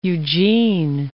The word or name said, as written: Eugene